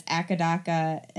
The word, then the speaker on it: AC/DC: So AC versus DC is pronounced one letter at a time, though the band colloquially known as Akadaka in Australia. So that's funny. – Akadaka? – Yeah, A-C-C-A, D-A-C-C-A, Akadaka.